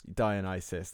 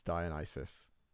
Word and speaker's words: Dionysus: Do you mean Dionysus? – Dionysus, that's how you pronounce it. Yes, Dionysus.